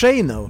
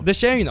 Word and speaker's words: Deschene: Alltså Rodin, Scott, Deschene, hur man uttalar det som först. – Deschene. – Deschene, okej, bättre där.